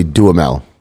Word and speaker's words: Duhamel: Well, my name is actually Duhamel. D-U-H-A-M-E-L.